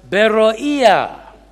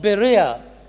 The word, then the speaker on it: Berea: Sometimes it is pronounced as Beroea, more literally, actually. The Greek city of Berea had existed since around 400 BC.